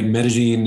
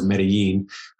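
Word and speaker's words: Medellín: Medellin is, well, for one, that's the way the locals pronounce it. Everybody else knows Medellin. – Yeah. I've just been down here so long that, you know, that's the way you pronounce it when you're here. So, but every other, even Spanish speaking country, certainly English speaking countries know it as Medellin,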